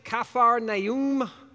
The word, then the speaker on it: Capernaum: I just was digging in and I'm like, well, that's not at all how we good Americans say Capernaum, Nazareth, Zebulun, Naphtali. Well, no. Nazareth, Capernaum, Zabulon, and Naphtalim.